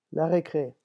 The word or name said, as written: la recre